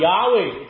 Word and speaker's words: Yahweh: I am Yahweh. It should be pronounced in Hebrew.